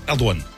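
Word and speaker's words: Erdoğan: Hein je, pense que ça se prononce Erdogan. – Erdogan Erdogan. Erdogan. Erdogan. Ok, Erdogan, si tu veux.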